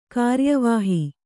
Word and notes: ಕಾರ್ಯವಾಹಿ: kāryavāhi

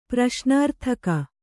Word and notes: ಪ್ರಶ್ನಾರ್ಥಕ: praśnārthaka

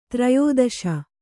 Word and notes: ತ್ರಯೋದಶ: trayōdaśa

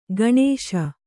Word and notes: ಕಣಿಶ: kaṇiśa

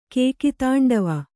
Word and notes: ಕೇಕಿತಾಂಡವ: kannada

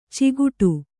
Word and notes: ಚಿಗುಟು: ciguṭu

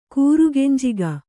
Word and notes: ಕೂರುಗೆಂಜಿಗ: kannada

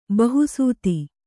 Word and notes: ಬಹುಸುತೆ: bahu sute

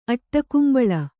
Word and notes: ಅಟ್ಟಕುಂಬಳ: Kannada

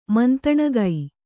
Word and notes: ಮಂತಣಗೈ: mantaṇagai

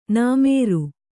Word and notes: ನೆಮರು: nemaru

